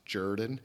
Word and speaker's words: Jordan: It's spelled like Jordan, like Michael Jordan, but pronounced Jordan.